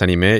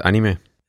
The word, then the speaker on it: anime: ¿En Argentina es anime y en Japón es anime? – Sí, acá la pronunciación es más como anime. Pero en Argentina yo siempre le dije anime.